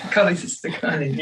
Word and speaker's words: cholecystokinin: Cholecystokinin, yeah.